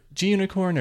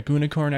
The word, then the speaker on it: Gunicorn: I've heard it like 35 different ways. Yeah. It's either JUnicorn or GUnicorn. I think both of them are commonly accepted.